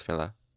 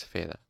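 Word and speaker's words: Fela: Thanks, Phila. Sorry, I should... That's... No. I put too much emphasis on the... Thanks, Phila.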